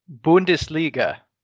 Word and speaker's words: Bundesliga: It is Bundesliga.